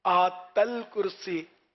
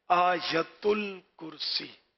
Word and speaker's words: آیت الکرسی: اچھا وہ آیت الکرسی، آیت ال، اس میں لوگ اس کو بھی آیت ال یا آیت ال ہے نا، آیت الکرسی، آت الکرسی، یوں بھی لوگ بول دیتے ہیں. تو اصل تلفظ ہے آیت الکرسی.